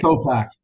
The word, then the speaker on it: Kopach: Kopak. H is silent.